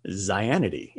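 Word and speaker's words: Xianity: In fact, Christianity was once originally spelled, as you would pronounce it, Zianity. It's X-I-A-N-I-T-Y.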